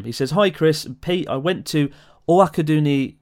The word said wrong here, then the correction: Owakudani: I can't pronounce that. That was awful. I went to Owakaduni. I love it. – Owakudani.